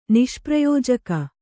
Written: ನಿಷ್ಪ್ರಯೋಜಕ

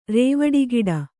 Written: ರೇವಡಿಗಿಡ

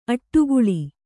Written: ಅಟ್ಟುಗೂಳು

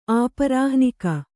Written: ಆಪರಾಹ್ನಿಕ

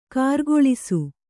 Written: ಕಾರಗಲಿಸು

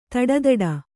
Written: ತೊಡೆದಟ್ಟು

